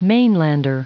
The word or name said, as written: mainlander